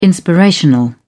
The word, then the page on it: inspirational: British English